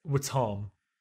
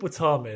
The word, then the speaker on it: Wattam: Ultimate, Halo Reach, Skyrim, Sims 3, Red Dead Redemption 2, Dirt 2, and Watam. – What? – Google what Watam is.